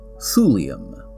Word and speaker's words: thulium: T-H-U-L-E is what's written on the map, and it's pronounced Thule. Despite that, the element is pronounced thulium.